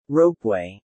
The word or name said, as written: ropeway